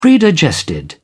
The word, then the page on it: predigested: British English